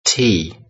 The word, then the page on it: tea: British English